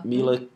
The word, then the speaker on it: Mila: Mila Kunis? – Yeah.